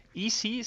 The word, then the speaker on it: EZDubs: esta plataforma que se llama Easy Easy se escribe EZ y Dubs es D-V de burro S, uh z Dubs, e a.